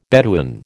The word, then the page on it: Bedouin: American English